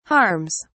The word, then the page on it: harms: British English